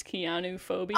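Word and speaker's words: chionophobia: I checked the pronunciation, and the clue is that it's pronounced like Keanu Reeves, Keanu phobia.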